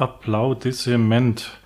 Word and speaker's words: Applaudissement: Der lautet Applaudissement. Genau. Das ist ein französisches Wort, wird aber deutsch ausgesprochen.